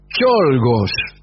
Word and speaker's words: Czolgosz: Hablaremos hoy de León Cholgos. Oh, sí. Amigo. En realidad se escribe León C. Z-O-L-G-O-S-Z. Usted sabe que en algunas lenguas la C y la Z hacen la Che.